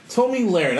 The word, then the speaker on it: Tomi Lahren: Speaking of Mike, Tomi Lahren. That's how you say her name.